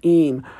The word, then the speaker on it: Eyam: The last story we have is the supposedly true and inspiring story of the English plague village of Eyme, spelled E-Y-A-M, but I believe it's pronounced Eyme.